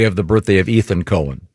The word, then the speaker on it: Cohen: This gets confusing because not when it's spelled C-O-H-E-N. – Yeah, it's definitely Cohen.